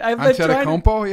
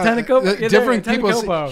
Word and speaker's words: Antetokounmpo: Antetokounmpo? Yeah. – Antetokounmpo.